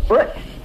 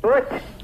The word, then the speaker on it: Butch: Butch. – What's your name? – Butch. – How you spell that? – B-U-T-C-H. – B-U-T-C-H.